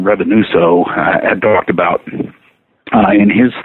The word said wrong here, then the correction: Revonsuo: Yeah, Revonsuo.